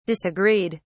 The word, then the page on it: disagreed: British English